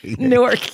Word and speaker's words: Newark: Newark. And you have to say it like that.